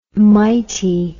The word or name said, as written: mity